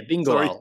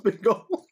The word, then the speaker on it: Bingoal: Yeah, Bingoal. It's Bingoal. That's how you pronounce it. Bingoal. Bingoal. Is it Bingoal? – It's Bingoal. – Bingoal, Wallonie Bruxelles. That's how you pronounce it. And yep, it's phonetically spelled that way.